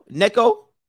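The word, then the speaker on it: Neko (ねこ): Or Nico? Neko? Neko? Neko, please.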